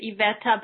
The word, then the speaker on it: Iveta: My name is Iveta, but yes, it's pronounced more like Yvette.